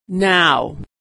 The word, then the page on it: now: aw